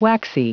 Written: waxy

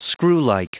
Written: screwlike

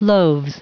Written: loaves

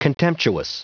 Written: contemptuous

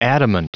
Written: adamant

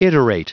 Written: iterate

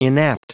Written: inapt

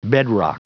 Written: bedrock

